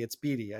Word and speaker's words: Beaty: Joc Pederson hit the IL, which means we're back in the Matt Beaty era, right on. Or Matt Beaty, it's Beaty. I got, finally got his name right.